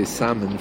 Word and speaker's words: salmon: Well, the L is silent, so I should really say salmon fillets, shouldn't I?